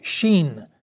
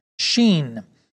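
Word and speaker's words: shin: and it's pronounced sheen, sheen.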